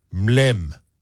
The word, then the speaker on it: mlem: If they do a thing called a mlem, and that's spelled M-L-E-M, okay.